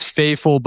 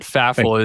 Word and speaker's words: Fafel: It is Faffel, but Faffel is acceptable at this point.